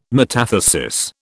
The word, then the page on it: metathesis: British English